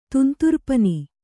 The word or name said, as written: ತುಂತುರ್ಪನಿ